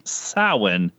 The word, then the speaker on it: Samhain: But when we did the research and saw it's Samhain, it's like, yeah, I would have never noticed that that's how you're supposed to pronounce it.